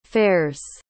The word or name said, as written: fares